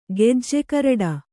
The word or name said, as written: ಗೆಜ್ಜೆಕರಡ